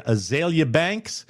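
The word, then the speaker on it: Azealia Banks: Rapper and Trump supporter Azalea Banks. Did I pronounce that correctly? – Yeah.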